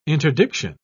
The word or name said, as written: interdiction